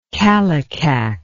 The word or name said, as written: kallikak